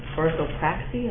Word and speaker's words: orthopraxy: And part 1 is orthodoxy, and part 2 is orthopraxy.